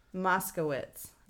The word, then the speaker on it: Moskowitz: Moskowitz, right? – Nailed it.